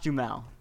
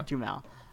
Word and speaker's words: Duhamel: How do you pronounce his name? – Josh Duhamel. Yeah, there you go. Duhamel.